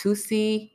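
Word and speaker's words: Toosii: He's verified on Twitter, so he ain't too much underground, but I believe his name is pronounced Tusi.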